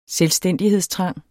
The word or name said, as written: selvstændighedstrang